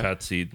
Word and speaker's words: Qatsi: Katsi. Well, that last, well, so Katsi. – Katsi. Okay. – Yeah, is the... Q-A-T-S-I, Katsi.